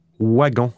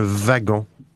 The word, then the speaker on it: wagon: OK, seuls les Belges ont l'habitude de prononcer wagon à l'anglaise. En France et en Suisse et au Québec, le W se prononce comme un V, un wagon.